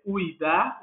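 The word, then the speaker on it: Ouidah: Ajudar ficou na pronúncia dos portugueses, que não conseguiam realizar a ideia de Uidá, que é o que os franceses colocaram, e que é o nome de Glerkwe, né, desse local na República do Benin, né?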